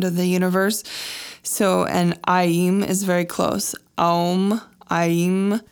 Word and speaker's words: Aim: So Aim, which is spelled A-I-M, but it's pronounced Aim, so it's often spelled A-I-E-E-M, just to make it more clear.